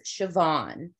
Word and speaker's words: Siobhan: Like one example that I can say is Siobhan, but it's spelled S-O-S-I-O-B-H-A-N, I believe. So their letter chunks sound make different sounds than what our sounds are. – Like that's a silent B.